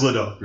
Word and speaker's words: Lidl: So, it's actually pronounced. It's actually. – Lidl. I was going to say, what the fuck is this? It's Lidl.